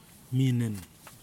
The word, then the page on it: minin: kwamera